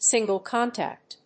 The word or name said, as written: single contact